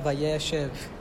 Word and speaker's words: Vayéshev: We are studying Parashat Vayéshev. I put an acento on the E of Vayéshev so people know how to say it. It wasn't a mistake. I put it there on purpose.